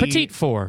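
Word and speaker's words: petit four: It's spelled like P-E-T-I-T. – Petite four.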